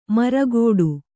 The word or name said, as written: ಮರಗೋಡು